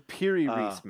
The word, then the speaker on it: Piri Reis: the Rere Reis map, or am I saying that wrong? – The Piri Reis map. – The Piri Reis map, thank you.